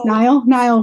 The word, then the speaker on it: Niall: Or I have a torrid illicit affair with Neil from One Direction. Niall from One Direction. Can't even say his name right.